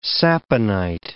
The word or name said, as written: saponite